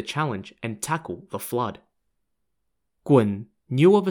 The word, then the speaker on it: Gun: Gun is spelt G-U-N, and no, it's not pronounced Gun, it's pronounced Gun, and ironically means big fish in Chinese.